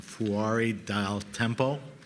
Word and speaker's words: Fuori dal Tempo: This is a, correct my pronunciation if I'm wrong, Fuori dal Tempo.